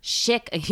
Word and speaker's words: Schick: How do you say that? – Schick.